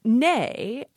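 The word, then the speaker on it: née: Here's one, N-E-E. How do you say that? – Well, nay, it would be how the French would say it. I think it's how most English speakers say it.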